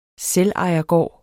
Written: selvejergård

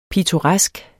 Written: pittoresk

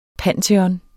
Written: panteon

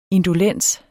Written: indolens

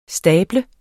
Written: stable

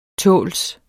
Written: tåls

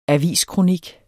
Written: aviskronik

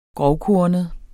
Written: grovkornet

